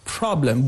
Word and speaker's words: problem: If you take the word like problem in English, the first syllable has a stress, problem.